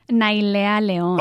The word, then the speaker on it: Nailea Leon: Nailea Leon.